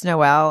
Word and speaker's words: Noel: Like the first Noel.